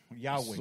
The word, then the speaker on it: Yahweh: It's actually supposed to be a breath out and a breath in. – Es cuando lo pronuncias debe de ser un aliento hacia afuera y un aliento hacia adentro, Yahweh.